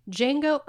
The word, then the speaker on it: Django: Django Unchained. The D is silent.